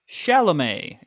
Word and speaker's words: Chalamet: How to pronounce it? – Chalamet. It's yes. – Chalamet.